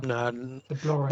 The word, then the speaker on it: Blorenge: No, the Blorenge. one of the lesser. What? Never. Not pronounced Blorenge, is it? No, the Blorenge.